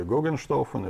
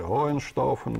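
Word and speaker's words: Hohenstaufen: Понятно, Штауфен или Гогенштауфен или Гоенштауфен, мы его можем по-разному произносить.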